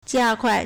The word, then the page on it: 加快: jiākuài